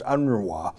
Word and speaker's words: UNRWA: It's a difficult acronym. It's UNRWA, pronounced UNRWA, United Nations Relief Works Agency for Palestinians.